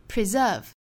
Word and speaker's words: preserve: Preserve, preserve. 注意重读音节在第二个音节 S 读 Z 的浊辅音 preserve.